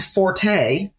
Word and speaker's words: forte: Fort and forte. Forte means loud. So when someone says her forte is this, you mean her loud is what? Forte does not mean strength. Fort is how you pronounce that. And when you're referring to someone's strength, forte is a musical term and it means loud.